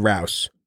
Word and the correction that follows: Roos: Although since there is no Ed Rouse, I can't mispronounce his name, but I still did. It's Ed Roos.